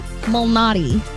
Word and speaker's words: Malnati: In golf, Peter Illuminati? – That's not it. And I was saying it right. It's just now I'm not saying it right. Peter Malnati. – Like the pizza place.